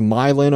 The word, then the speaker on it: Milan: It is Milan, Ohio.